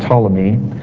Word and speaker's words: Ptolemy: Now, back in 300 BC, there was a man named Ptolemy, and that's spelled with a P in the front, Ptolemy.